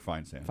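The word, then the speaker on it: Feinsand: Mark Farisand. – Mark Finesand. – Finesand. Said his name wrong, yeah.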